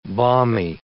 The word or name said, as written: balmy